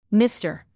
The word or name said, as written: mister